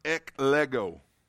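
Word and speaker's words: eklego (ἐκλέγω): This word "chosen" is a Greek word that is pronounced eklego.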